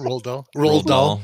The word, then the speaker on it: Roald Dahl: Roald Dahl.